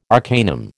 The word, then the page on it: arcanum: American English